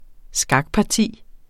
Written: skakparti

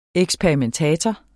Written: eksperimentator

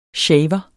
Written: Shavere

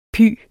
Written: py

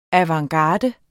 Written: avantgarde

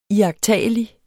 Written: iagttagelig

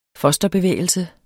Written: fosterbevægelse

